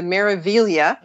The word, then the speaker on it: Meraviglia: Okay, the new ship, which is still under construction, is the Meraviglia.